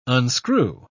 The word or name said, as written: unscrew